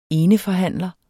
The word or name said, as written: eneforhandler